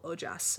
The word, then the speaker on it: ojas: This substance is called ojas, and that's spelled O-J-A-S.